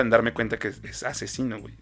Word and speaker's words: Aczino: Eh, el siguiente que yo traigo es Asesina. – Asesino. – Ajá, que se escribe A-C. Z-I-N-O.